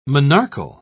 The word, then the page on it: monarchal: British English